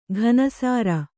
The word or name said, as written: ಘನಸಾರ